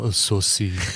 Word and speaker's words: Sosie: It's pronounced Sosie.